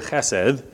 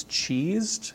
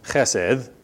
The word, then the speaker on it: chesed: It's been a while since we talked about that fun little Hebrew word, chesed. One of my unnamed children thinks it's cheesed, because in English we usually spell it C-H-E-S-E-D. Chesed, nothing like cheese, daughter.